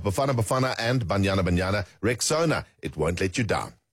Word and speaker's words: Rexona: It's pronounced Rexona, simple, strong, and easy to remember.